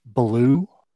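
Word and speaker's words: Beaulieu: And his name is, I believe the way it's pronounced is Trace Ballou, but B-E-A-L-L-I-E-U.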